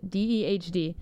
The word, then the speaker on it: Dehd: How did Abby pronounce it? DEHD. It's totally pronounced in like a niche way, but yeah.